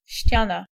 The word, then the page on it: face: France; [fas]